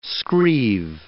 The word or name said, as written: scrieve